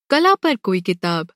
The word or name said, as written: किताब